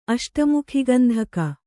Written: ಅಷ್ಟಮುಖಿಗಂಧಕ

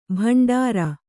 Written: ಭಂಡಾರಿ

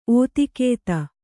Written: ಓತಿಕೇತ

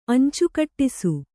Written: ಅಂಚುಕೂಡಿಸು

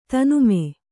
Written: ತನ್ಮೆ

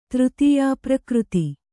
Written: ತೃತೀಯಾಪ್ರಕೃತಿ

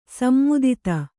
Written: ಸೀಮಿತತೆ

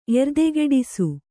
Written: ಎರ್ದೆಗಿಡಿಸು

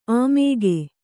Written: ಆಮೋಘ

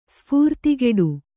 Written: ಸ್ಫೂರ್ತಿಗೆಡು

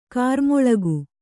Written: ಗಿರಿಮಲ್ಲಿಕೆ

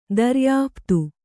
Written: ದರ್ಯಾಃಪ್ತು

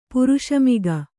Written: ಪುರುಷಮಿಗ

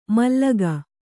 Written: ಮಾಲಕ

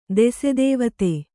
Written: ದೆಸೆದೇವತೆ

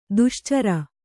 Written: ದೋಷಾಚರ

